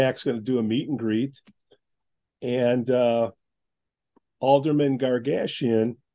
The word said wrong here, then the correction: Goshgarian: Goshgarian, did I say that right? Because I don't want to screw up his first name. So Ara. Ara Goshgarian. Goshgarian. That's what I said.